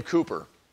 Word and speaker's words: Cowper: One of the greatest masters of the English language in the 18th century was the mentally troubled and theologically deep William Cooper, which is spelled Cowper, just so you know.